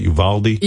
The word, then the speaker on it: Uvalde: I was impressed with him making a speech about the kids who died in Uvalde. Is that how you say it? Uvalde? – Yes.